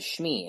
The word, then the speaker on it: Shmi: They wanted to know the first name of Anakin's mother, and her name is Shmi, and it's spelled S-H-M-I, and Janine spelled it wrong. She spelled it like S-C-H-M-E-E, I think, something like that. But phonetically, that's correct. You know, if you're saying it, Shmi, you know, so you take that word and you say that word, it's said correctly.